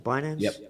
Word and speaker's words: Binance: Binance, the world's largest, is that the way you pronounce it? Binance? – Yep.